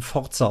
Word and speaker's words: Forza: Ich glaube, es wird tatsächlich im Deutschen Forza ausgesprochen, was ich immer so lustig fand, weil es sich nach Furzen anhört.